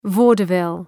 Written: vaudeville

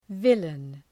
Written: villain

